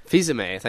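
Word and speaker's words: Fils-Aimé: is it Philo-me? Is that how you pronounce his last name? – Fizame, thank you.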